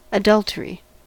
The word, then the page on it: adultery: American English